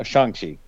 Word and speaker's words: Shang-Chi: And I would like to recommend, I just watched a couple hours ago, I watched Shang-Chi, since it just dropped on Shang-Chi. Got to give their correct pronunciation.